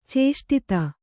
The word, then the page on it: ಚೇಷ್ಟಿತ: Kannada